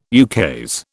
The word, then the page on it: ukase: British English